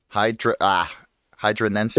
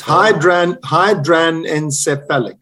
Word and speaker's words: hydranencephalic: So the hydranencephalic. – It's hydranencephalic.